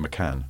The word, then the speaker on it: Macan: Macan. – Macan. Oh, It's the second A sound is long, because it's also Taycan here.